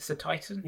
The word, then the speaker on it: Cetitan: And I'm not sure how to pronounce Cetitan, the ice whale. Is it Cetitan? – Yeah, I'd say so.